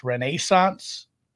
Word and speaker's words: Renaissance: I will say it is pronounced Renaissance. That's how they say it over there.